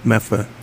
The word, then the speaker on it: Medford: Meffa. – Oh, you say it right. – Right, yeah. – Or the way the hockey players say it from Medford.